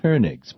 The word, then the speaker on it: Koenigs: The German pronunciation would be Koenigs, but K-O-E-N-I-G-S.